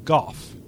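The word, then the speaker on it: Gough: However, Go pronounces his own name Goff. Which I feel also means our Lardy Executioner's name is Smoff.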